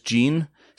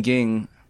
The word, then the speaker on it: Ging: The thing is that in the English dub, they actually pronounce it as Jean. So, they kept mentioning this Jean guy. It's like, who the hell are they talking about? And I thought, I was like, oh, they mean ging.